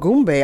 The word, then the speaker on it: gombo: I mean, gumbo is the French word for okra and it's called gombo.